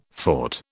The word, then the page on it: fought: British English